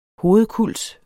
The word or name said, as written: hovedkulds